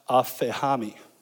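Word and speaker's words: aphehami (ἀφίημι): And that word leave, in the Greek, it's actually pronounced aphehami. Aphehami, and it means to depart or abandon.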